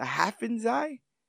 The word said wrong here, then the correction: Hafensee: I think it's Hafensee. – Hafensee? – Yes. That's how I always read it, the Hafensee.